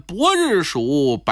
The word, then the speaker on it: 白日鼠: 您经常听我们说书啊，就是是这个白的音都发伯。比如说水浒传有个白日鼠白胜，那么我们怎么念呢？就念伯日鼠白胜。